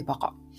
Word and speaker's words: 馬鹿: 馬と鹿と書いてバカ。